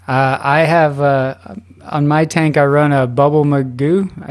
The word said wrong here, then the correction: Bubble Magus: Is that how you say it? Magus? – I say Bubble Magus.